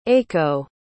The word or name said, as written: eiko